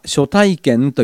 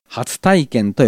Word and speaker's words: 初体験: 正しい日本語としては初体験と読むのが普通なんですけども、この映画のタイトルはあえて初体験と読ませています。